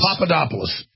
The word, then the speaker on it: Papadopoulos: Papadopoulos.